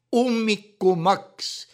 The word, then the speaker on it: ummikumaks: Ummikumaks ja turismimaks. Ma ütlesin ummikumaks, ma peaaegu hääldan seda knaklaudiga, mõned võibolla teavad, mida see tähendab, mitte kummikumaks, mis on seotav konkreetse seenelisega.